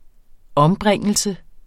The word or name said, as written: ombringelse